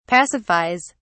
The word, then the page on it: pacifies: British English